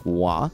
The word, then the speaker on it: Roy: Nothing anywhere that says that should be pronounced Wah. I think it's funny that he insists on it. I think that is probably more... What? If, to be fair, we've been making fun of his name for a while. – Patrick Roy demanding that people say his name is Patrick Wah.